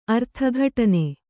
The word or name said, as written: ಅರ್ಥಘಟನೆ